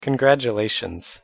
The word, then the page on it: congratulations: English